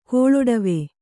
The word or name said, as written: ಕೋಳೊಡವೆ